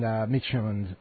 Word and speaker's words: Midtjylland: Did I say it right that time? – Midtjylland, yes.